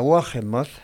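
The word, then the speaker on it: Wolgemuth: a Wohemoth, aprendí a decir su nombre porque escuché cómo ella lo pronuncia,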